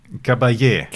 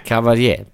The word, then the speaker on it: Caballé: Montserrat Cabal. Nein. – Caballé. – Caballé. Bitte nochmal als Ganzes zum Reinschneiden. – Montserrat Caballé.